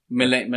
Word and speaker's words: Milena: like Malina DeMartini. – Oh yes, Malina. – Milena. Milena. Yeah.